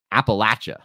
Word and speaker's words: Appalachia: It's pronounced Appalachia.